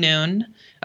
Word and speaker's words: Noone: Talk Mental Health with Logan Noon. Noon is spelt like no one smushed together.